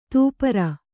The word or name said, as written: ದಪ್ಪರಿ